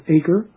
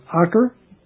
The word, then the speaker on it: Acre: which could be mispronounced Acre, but it's Acre.